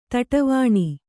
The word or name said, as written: ತಟ್ಟಿವೆಣೆ